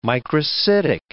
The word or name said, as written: microcytic